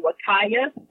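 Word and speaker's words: Huacaya: There's the Wakaya, and that's spelled with an H, not a W. It's a Peruvian name. And there is the Suri, which is spelled S-U-R-I.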